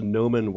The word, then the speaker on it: Gnomon: the Noman Workshop, which is spelled G-N-O-M-O-N.